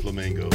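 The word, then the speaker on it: Flemingo: DJ Flamingo. So last name is spelled Fleming. Just add an O to the end of it. That's how you spell it.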